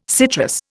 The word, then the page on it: citrus: Indian